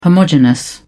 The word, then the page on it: homogenous: British English